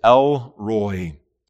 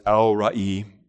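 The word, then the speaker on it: El Roi: In the English, we would say, the name being used here, the way we would say it is Elroi, E-L space R-O-I. In the Hebrew, it's not pronounced that way. In the Hebrew, it's Elroi, but either way, that's the name being used here.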